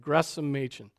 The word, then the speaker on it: Gresham Machen: You corrected the pronunciation. Gresham. You don't pronounce the H, right? Gresham Machen.